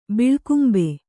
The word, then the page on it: ಬಿಳ್ಕುಂಬೆ: biḷkumbe